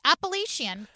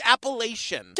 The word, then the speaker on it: Appalachian: Appalachian community. What? Am I saying it wrong? – Yeah. Yes, it's Appalachian.